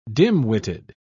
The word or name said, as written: dimwitted